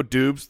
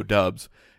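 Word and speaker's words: Doubs: Romeo Dobbs, but it's spelled D O U, and it was like Romeo Dubes that Okay. And then we were alerted. No, it's Romeo Dubs.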